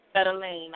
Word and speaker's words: Fedeline: It's pronounced Fedeline, but thank you for asking. – Fedeline, I'm sorry.